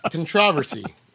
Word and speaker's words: controversy: I remember the first time I heard that, I'm like, wow, is that how they say it in England? Controversy? – Do they? Yes. – Do they the answer controversy? – And the answer is yes. – Wow. Huh. How about that? – If you're with the BBC, that is indeed the way you're introducing it. – Well, I guess they just love pronouncing words wrong.